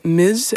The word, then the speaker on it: Ms: Maar wat je ook vaak op ziet komen is het overkoepelende Ms. En dat spreek je dan uit met een Z, maar je schrijft het als MS.